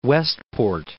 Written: Westport

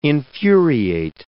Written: infuriate

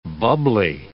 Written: bubbly

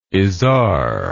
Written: Izar

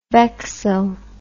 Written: vexil

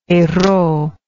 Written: Herault

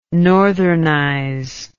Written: northernize

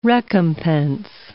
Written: recompense

